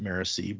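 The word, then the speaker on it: Marasi: And her cousin Marisai, or Marisi, I think I've heard Brandon actually pronounce it Marisi, but I've always said it Marisai in my head. So whatever you like.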